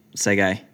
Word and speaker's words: Sege: And then my tip, Sege, which I was pronouncing yesterday, Teske, silent T apparently.